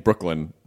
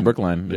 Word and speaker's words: Brookline: I always feel like that's an arrogant person saying Brooklyn, but Brookline. – No, Brookline. – Yeah. – It's spelled L-I-N-E.